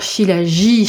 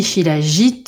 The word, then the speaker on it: Shilajit: Alors oui, euh, qu'on soit bien d'accord, Shilaji, Shilajit, euh, il y a un T à la fin, vous le prononcez, vous le prononcez pas. Oh, on va... – on, fait, comme... – Non, voilà, on, va faire comme, euh, comme on veut. C'est voilà, Sachez qu'il y a un T à la fin qui ne se prononce pas forcément, effectivement.